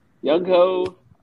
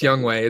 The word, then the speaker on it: Young-ho: And to round things out of the top 5, Mr. Nice Gay Guy Joe grabs South Korean kicking superstar Young-ho Koo. – Oh, Youngway Koo. – Youngway, is it? I thought it was Youngho. Is it Youngway? – It's Youngway. It's spelled like Youngho though.